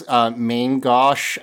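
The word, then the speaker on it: main gauche: It's Mangosh, I think. Mangosh.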